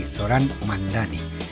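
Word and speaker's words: Zohran Mamdani: llamado, a ver cómo lo digo yo, porque tiene Zoran Mandani. Creo que se pronuncia así, por lo menos él nos dice que se pronuncia así, Zoran Mandani.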